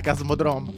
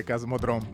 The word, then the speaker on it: Kosmodrom: Ho detto bene Cosmodrome? – Sì, sì, ma immagino di sì.